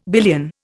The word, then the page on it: billion: Indian